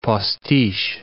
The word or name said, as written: postiche